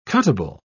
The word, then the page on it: cuttable: British English